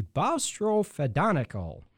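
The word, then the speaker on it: boustrophedonical: So the Great Land Survey System that became known as the Bostro-Fedonico, say that a few times, Bostro-Fedonico.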